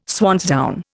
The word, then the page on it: swansdown: Indian